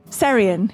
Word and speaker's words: Seirian: Seirian.